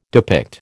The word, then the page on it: depict: American English